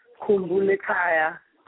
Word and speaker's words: Kumbu Lekaya: And this is, if I can pronounce it correctly, Kumbu Ekeya? – Kumbu Lekaya. – Kumbu Lekaya. Okay, excuse me.